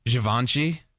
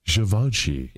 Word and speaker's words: Givenchy: Well, how do you say it? – Givenchy. – Givenchy.